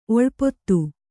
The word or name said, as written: ಓಲಪಾತ